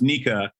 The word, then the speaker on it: NECA: So there's NECA, I think it's pronounced Nika.